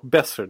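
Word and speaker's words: Boeser: Men jag säger ju Brock Besser, det är det uttalet.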